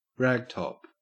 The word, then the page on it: ragtop: Australian English